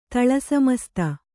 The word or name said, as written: ತಳಸಮಸ್ತ